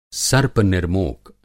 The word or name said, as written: सर्प-निर्मोक